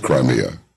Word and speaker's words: Crimea: All right, and would you mind telling everybody the correct pronunciation of C-R-I-M-E-A? – Crimea. – It is Crimea. – Yeah, in Russian we say Krim, Krim. – Krim. – But in English, Crimea.